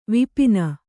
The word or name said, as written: ವಿಪಿನ